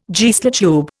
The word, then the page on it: Geissler tube: Indian